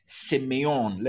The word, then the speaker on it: semeion: The Greek for sign is semeion. Let me spell it for you. semeion. S E M E I O N.